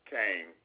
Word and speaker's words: Cain: Robert Kane. Kane is spelled C-A-I-N.